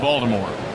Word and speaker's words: Baltimore: A humid but lovely summer night for baseball in Baltimore. Here's the pitch. Outside, two balls, no strikes. That is the proper pronunciation. Baltimore might have a T in the spelling, but nobody around here pronounces it ever.